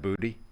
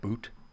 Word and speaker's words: Boutte: That's really how you pronounce that, Boutte? – Yep. – Perfect. – Looked it up. – Okay. – What would you go with? – I would have said boot based off how it's Boutte.